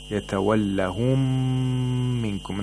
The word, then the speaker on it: يتولهم منكم: We don't pronounce the first sakin one. We only pronounce the second one, but with shatta and with more intensity. Yatawallahum minkum.